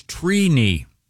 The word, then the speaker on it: Trini: The correct pronunciation is Trini. – Wow. Trini. – Like T-R-E-E.